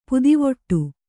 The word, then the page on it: ಪುದಿವೊಟ್ಟು: pudivoṭṭu